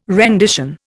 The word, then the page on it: rendition: Indian